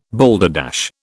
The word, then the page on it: balderdash: British English